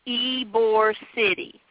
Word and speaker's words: Ybor City: But the 10th of January, which is Friday, they're going to be in Ybor City.